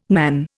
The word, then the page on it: nan: Indian